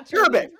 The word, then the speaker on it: cherubic: stand-up album coming out called Cheru- cherubic. Did I say it right? yeah, cherubic. – Cherubic. Cherubic. – Like cherub.